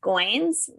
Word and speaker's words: Goins: Um I really like Real Artists Don't Starve by Jeff Goins, rhymes with coins.